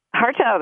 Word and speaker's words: Hartough: Did I get that name correct? Hartow? – Hartow, that's right. – Awesome. But it's spelled H-A-R-T-O-U-G-H.